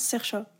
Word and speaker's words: Saoirse: Mais ça se prononce Sercha.